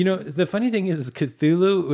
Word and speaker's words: Cthulhu: I don't understand why, because it's just Cthulhu. That's just what it's been. I've heard Cthulhu, and I've heard just some other weird. It's Cthulhu.